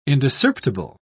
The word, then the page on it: indiscerptible: British English